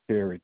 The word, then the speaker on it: Terry: Terry, Terry. – T-E-R-R-Y, okay.